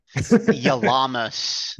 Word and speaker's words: Yalamas: Yalamas.